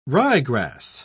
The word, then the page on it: ryegrass: British English